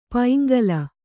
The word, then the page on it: ಪೈಂಗಲ: kannada